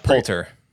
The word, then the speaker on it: Poulter: Yeah, Adam Warlock is probably the thing that salvages this whole thing for me because Is it Will Coulter? Is that Poulter.